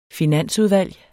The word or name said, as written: finansudvalg